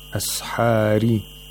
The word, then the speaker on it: أَسْحَارِ: أَسْحَارِ. The ra has tarqiq because it has a kasra. It has to be pronounced with thinness.